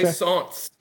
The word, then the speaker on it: Plaisance: Yeah, it's got multiple pronunciations, but I'll take that one. Okay. Plaisance is the like Renaissance.